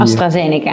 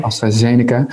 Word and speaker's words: AstraZeneca: Oh, ik weet niet waar de klemtoon ligt bij die. – AstraZeneca. Eh, AstraZeneca.